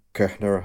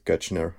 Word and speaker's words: Koechner: Then we have David Koechner. Kuchner, how do you pronounce that? Kuchner. Let's go with that.